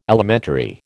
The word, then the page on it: Alimentary: American English